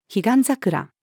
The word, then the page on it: 彼岸桜: female